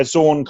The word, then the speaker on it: Xorn: You know, there's this period within I think it was New X-Men, the Grant Morrison stuff, where I never know again how to pronounce this. Is it Zorn? – X-O-R-N. – Yeah, where Zorn kind of teaches like a side group,